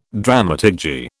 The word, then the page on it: dramaturgy: British English